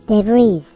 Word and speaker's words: debris: 同じスペルで、複数形になると、デブリーって発音するみたい。